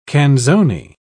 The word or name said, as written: canzone